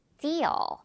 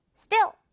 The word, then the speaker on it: steal: steal. 이렇게 띠 발음이 난다는 거. steal. 자, 그리고 여기는 지금 여러분, 모음이 좀 많죠? 두 개나 했어요. 그리고 소리도 길어져서, Still 이렇게 짧게 하지 말고 steal 길게 끌어줍니다.